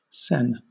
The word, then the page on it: sen: British English